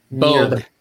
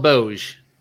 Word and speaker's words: Bogue: Bogue, okay. – Yeah. You make sure it's spelled Bouge, but it is pronounced Bogue.